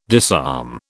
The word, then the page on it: disarm: British English